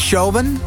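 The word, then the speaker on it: Siobhán: Toen had je dus Mucha, Keisha en Shobin. Dat is op mijn beste Iers uitgesproken.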